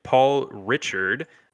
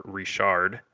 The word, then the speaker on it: Ricard: Uh, circuit Paul Richard, uh, or Ricard, as it's probably pronounced, uh, is up next for the for the circuit.